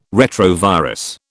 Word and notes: retrovirus: British English

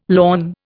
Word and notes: lorn: Indian

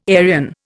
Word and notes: Arian: Indian

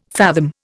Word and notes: fathom: Indian

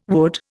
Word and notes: wood: Indian

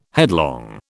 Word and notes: headlong: British English

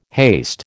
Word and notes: haste: American English

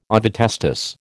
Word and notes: ovotestis: American English

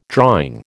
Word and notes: drawing: American English

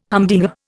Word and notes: humdinger: Indian

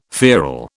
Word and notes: feral: British English

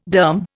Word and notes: derm: Indian

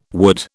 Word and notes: would: British English

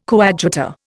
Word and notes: coadjutor: Indian

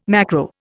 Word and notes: macro: Indian

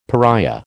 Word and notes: pariah: American English

